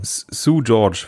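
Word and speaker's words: Seu Jorge: Sue George wird er ausgesprochen. Er schreibt sich S-E-U und dann Jorge, also Jorge.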